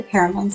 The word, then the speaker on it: Perelman: A pediatrician at the Children's Hospital of Philadelphia and a clinical assistant professor of pediatrics at the Perelman School of Medicine. Am I even saying that correctly? – Yeah, the Perelman School of Medicine.